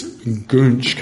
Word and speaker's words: goonch: North Indian rivers are also home to the hideous goonch catfish. Let's see if I can get this right. There it is. Goonch is the pronunciation.